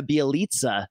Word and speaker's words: Bjelica: Klay Thompson is probable, but Nemanja Bjelica, I had to take a Serbian lesson to make sure I was pronouncing that properly, and Draymond are all still out.